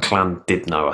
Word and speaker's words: Llandudno: We did a gig in, I can't say it, it's Llandudno. That's not how you say it. It's like Llandudno, I think you say it.